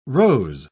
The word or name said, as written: rose